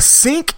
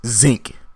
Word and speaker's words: sink: But a sink is not pronounced zinc.